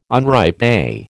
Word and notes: unripe: American English

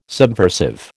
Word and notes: subversive: American English

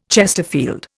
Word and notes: chesterfield: Indian

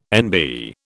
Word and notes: NB: British English

